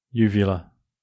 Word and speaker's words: uvula: What, uvula? – Uvula.